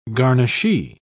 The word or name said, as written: garnishee